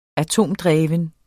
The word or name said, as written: atomdreven